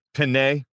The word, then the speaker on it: Penei: How do you pronounce it? – Penei. Yes.